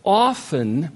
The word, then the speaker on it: often: But when it is his will to answer our prayers, often, by the way, it's not often, as it sounds so nice to say often. Check it out in the dictionary. The T is not to be pronounced.